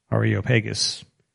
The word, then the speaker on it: Areopagus: I should pronounce the Areopagus, apparently. Areopagus.